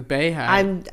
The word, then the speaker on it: Beyhive: The bayhive. – We're cutting that. Out. – It's gotta be. How do you say it? It's the Beyhive.